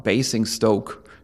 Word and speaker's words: Basingstoke: Basingstoke, ich hoffe, dass ich das richtig ausspreche.